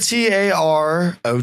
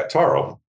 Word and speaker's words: tarot: No, uh, no I said T A R O T. – Yeah, Taro. Taro. – Taro. – That's how you say it.